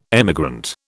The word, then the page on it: emigrant: British English